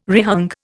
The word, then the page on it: rehung: Indian